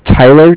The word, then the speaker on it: Tyler: My name is Tyler, T-Y-L-E-R. – Yes. – And I'm calling with Cardiff. It's spelled C-A-R-D-I-F-F, as in Frank Frank.